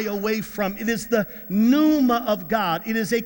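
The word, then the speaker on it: pneuma (πνεῦμα): And then when you get to the New Testament or the Greek, the word is pneuma, pneuma. The P is silent. The word is pneuma.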